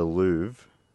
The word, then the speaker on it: Louvre: But to answer your question about the Louvre, am I saying that right? – Yeah.